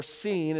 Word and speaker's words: Sin: They've gone across the Red Sea and made their way south through the wilderness of Sin, or Sin is actually how that's pronounced, even though it's spelled like our word sin, all the way down to the bottom of this peninsula where Mount Sinai is.